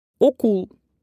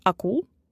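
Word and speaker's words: okul: Поэтому если в слове О, то нужно произносить как О и не изменять на А. Так, например, школа — окул, а не акул.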